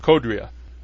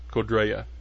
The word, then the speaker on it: Codria: The way he explained it to me when I had him on the radio show here a couple years ago, he said, he said it's like Gloria. It's Codria, not Codrea, as a lot of people say. That's what I used to call him too, but that's the proper way to pronounce it.